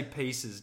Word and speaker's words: Reese's Pieces: Did you just say Reese's Pizza? – Reese's Pizzas. Reese's Pizzas. I can't say it properly. – Say it again. You can't. – I can't. – Say it again. Wait, Reese's wait. Just say pieces.